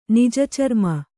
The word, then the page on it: ನಿಜಚರ್ಮ: Kannada